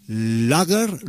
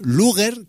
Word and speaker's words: Luger: pues ha sido una película española llamada Lager, Luger, que decían, pues no, que no tenéis que decir Lager, que Lager es una cerveza, hay que decir Luger.